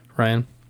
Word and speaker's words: Rian: How do you say his name? Ryan.